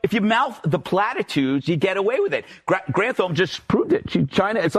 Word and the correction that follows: Granholm: like Granholm. – Granholm. – It's Granholm. Granholm. Pronounce it correctly.